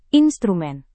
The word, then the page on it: instrumen: indonesian